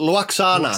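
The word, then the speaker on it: Lwaxana: Luoxana. – Luoxana, yeah.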